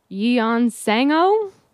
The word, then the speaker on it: Yeon Sang-ho: Yeon Sang-ho.